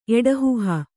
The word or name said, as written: ಎಡಹುಹ